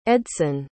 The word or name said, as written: edson